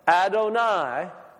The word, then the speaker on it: Adonai: The Lord, Adonai. It's easy to remember it because it rhymes with El Shaddai.